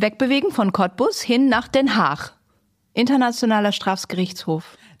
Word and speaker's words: Den Haag: Das ist nämlich ein niederländischer Ort und das G in Niederländischen wird ausgesprochen. – Den Haag.